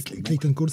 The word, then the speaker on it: Clayton Cowles: Lee Lourage et Clayton Cowles. Je prononce très mal, c'est Clayton Cole, c'est juste lettreur en fait.